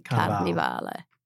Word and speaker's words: Carnivale: It is actually Carnivale. – Carnival, you don't even pronounce the E.